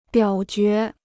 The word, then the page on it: 表决: biǎo jué